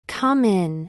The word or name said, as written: come in